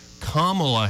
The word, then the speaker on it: Kamala: Kamala Harris.